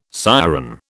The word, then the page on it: siren: British English